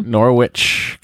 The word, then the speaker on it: Norwich: We don't pronounce the W. – Norwich, Connecticut.